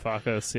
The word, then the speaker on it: Varoufakis: Varoufakis, you got it.